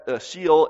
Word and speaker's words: Sheol: In most of the Old Testament, they believed there was a place called Sheol, sometimes known as Hades, but it's pronounced Sheol, S-H-E-O-L.